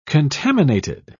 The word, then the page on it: contaminated: British English